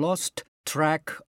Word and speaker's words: lost track: Lost track. これは t を個別に発音すると大変言いにくいので、もう本能的と言いますか。 Lost track と最初の t を言わずに次の語につなぐと楽です。